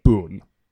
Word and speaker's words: Bun: is even though the name is spelled, like, in the subtitles, bun, like B-U-N, like the English word for, you know, a bun. They are actually saying Boone.